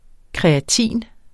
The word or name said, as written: kreatin